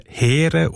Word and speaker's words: Heer: Das Wort spricht man im alten Schweizerdeutsch mit Längung E als Heer aus.